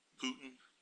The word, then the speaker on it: Putin: Putin.